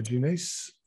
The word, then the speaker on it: Jeunesse: And so you're the lead editor of, how is this pronounced? Jeunesse. – Yeah, you're pretty much bang on the money.